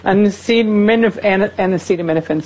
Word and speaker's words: acetaminophen: Acetophen. – What is that word? – Acetophen. – That's a hard one. How do you say it? Acetaminophen, sir.